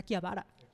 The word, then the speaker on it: Akihabara: Por ejemplo, a mí me corrigieron que Akihabara no se dice Akihabara, así se dice Akihabara.